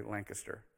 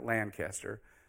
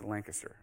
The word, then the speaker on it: Lancaster: By the way, if you're from Pennsylvania, you say Lancaster. All right, it's not Lancaster. It's Lancaster. All right.